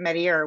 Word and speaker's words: Metier: This Pointe Metier, and it's pronounced like the French way would be Metier, but of course the residents pronounce it Metier, which is again, sort of adds to this sort of, the ambiance of this group.